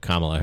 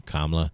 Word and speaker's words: Kamala: Well, yesterday after listened to the Barack Obama speech for Joe Biden and Kamala Harris And I said that right. I always say Kamala. I don't know why I make it a long, you know, a sharp A, but Kamala Harris.